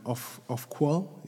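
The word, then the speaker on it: Ofqual: Yeah, so it, I, I did see some figures today from um, Off qual. Is that how you pronounce okay. it? off yeah.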